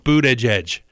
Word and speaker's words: Buttigieg: You're supposed to pronounce it Buttigieg.